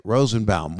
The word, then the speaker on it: Rosenbaum: That's how you say it, Rosenbaum.